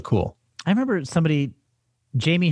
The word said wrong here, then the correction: Jaime: Hernandez, the the illustrator of Love and Rockets comics, or Jaime Hernandez, I guess is, is how his name is pronounced.